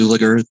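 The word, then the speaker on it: Zuleger: My name is James Zuliger. That's spelled Z as in zebra, U-L-E-G-E-R.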